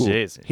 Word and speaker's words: Gies: Oh, jeez. – He's a fox. – Yeah, and his name is Jeez. – Jeez. – His name is Jeez. – But Jeez? – It's spelled G-I-E-S.